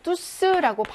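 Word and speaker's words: tous: 대명사에서는요, 여성 복수 형태는 없고, 뚜스라고 발음을 해야 되죠.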